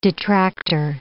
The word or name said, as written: detractor